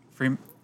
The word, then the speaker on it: Fremen: Fremen. They're called Fremen.